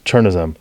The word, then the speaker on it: chernozem: Churnism.